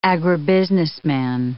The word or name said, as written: agribusinessman